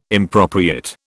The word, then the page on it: impropriate: British English